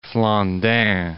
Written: Flandin

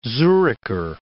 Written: Zuricher

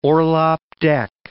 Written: orlop deck

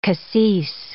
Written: cassis

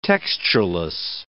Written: textureless

